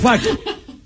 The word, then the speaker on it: Affleck: Affleck. Good job, Steve. – Thank you. – You got his name right.